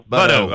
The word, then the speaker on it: Buddo: Buddo.